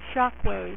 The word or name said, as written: Shockwave